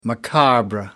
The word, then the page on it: macabre: American English